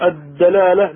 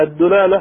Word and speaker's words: الدلالة: المصنف يشرح فيقول: اعلم أن الدلالة مثلثة الدال، يعني تنطق الدلالة بفتح الدال أو بضمها الدلالة أو بكسرها الدلالة. قال: والأفصح فتحها ثم كسرها وأردأها الضم. يعني الأفصح لما يقول فتحها تقول الدلالة، الذي يلي ذلك الدلالة، والأردأ الدلالة، وهي في الاصطلاح فهم أمر من أمر.